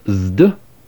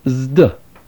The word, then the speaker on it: zeta: These are the letter zeta, often called zeta, pronounced zd, as the letters sd are pronounced in the English word wisdom, zd.